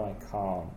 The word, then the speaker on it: can't: Can't tiene el sonido vocal más largo. – Exactly. – Aunque no escuchas la T final... Mm. Puedes saber que es negativo por lo largo que es el vocal. Can. – That's right. – Yes, I can. No, I can't. – It's a longer vowel sound. It's the same sound as car.